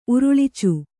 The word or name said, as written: ಉರುಳ್ಚು